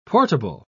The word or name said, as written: portable